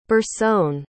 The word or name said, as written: bursone